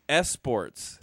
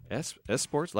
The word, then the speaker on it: esports: And then the last one is esports. – Esports? Like competitive tournaments of video games. – No. – Esports is what it is. But it's spelled E-S-P-O-R-T-S, so I just want to say esports.